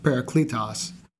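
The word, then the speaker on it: parakletos (παράκλητος): One of my favorites is something I learned probably in the last few years, and it's a Greek word that's pronounced parakletos. And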